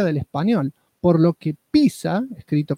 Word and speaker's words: pizza: Así que a raíz de este tuit aclaró que al ser un extranjerismo no adaptado, la forma correcta de pronunciar pizza es pizza, con la T antes de la SA.